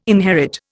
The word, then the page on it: inherit: Indian